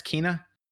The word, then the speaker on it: Kena: And that's apparently I've been pronouncing it wrong. It's not Kena, it's Kena.